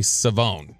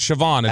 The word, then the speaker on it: Shavon: And we were informed on Friday night, it's actually Savone. So it is not Siobhan. It is Savone. – How does he spell it? – S-H-A-V-O-N. Which looks like Siobhan. – Siobhan. – Yeah. – But it's Savone.